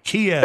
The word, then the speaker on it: Kyiv: And then you were in Kyiv. How do you pronounce that? Ukraine. – Kyiv. – That's correct. Yes.